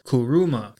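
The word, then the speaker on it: kuruma (車): Today's Lessons is going to be the Japanese word for car, and that is kuruma. Kuruma is spelt K-U-R-U-M-A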